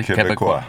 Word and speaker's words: Québécois: So you're Québécois, as they say. – Oui, monsieur. – Okay, so I'm saying that correctly? – Québécois.